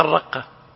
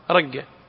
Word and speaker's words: الرقة: من ينطقها الرقة؟ ولا أحد. تكتب الرقة، كيف نقرأها؟ رقة، صح ولا لا؟